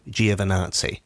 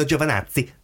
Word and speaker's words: Giovinazzi: I think I leaned on Nazi a little bit too heavily. – You did, yeah. – But Antonio Giovinazzi.